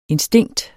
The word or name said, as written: instinkt